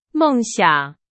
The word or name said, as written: 梦想